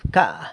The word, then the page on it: bca: San Lucas Quiaviní